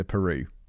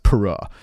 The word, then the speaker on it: Peru: I did go on a gap year, and I didn't go to Peru, even though it's very popular, although it's technically pronounced Pura.